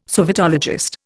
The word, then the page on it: sovietologist: Indian